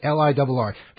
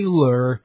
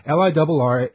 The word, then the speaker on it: LIRR: Look, that's the only way you pronounce it. That's the only way people around here say it, LIRR. They don't say lur. Nobody says that. They don't say LIRR. I mean, some people might, but they're quickly corrected. LIRR is the right way to say it.